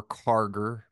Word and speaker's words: Karger: go follow me at jack underscore karger my last name is spelled k-a-r-g-e-r go follow uh,